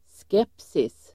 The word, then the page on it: skepsis: [sk'ep:sis]